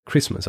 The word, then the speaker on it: Kiritimati: Und äh, es gibt zum Beispiel ein Atoll dort, das schreibt sich Kiritimati, wird aber eigentlich wie Christmas ausgesprochen.